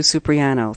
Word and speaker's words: Supriano: My name's Sue Supriano.